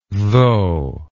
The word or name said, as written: tho